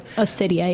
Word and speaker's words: Osteria: Nancy seems so centered when she's at the mozzarella bar at, how do you say it, Osteria? – Osteria, yeah.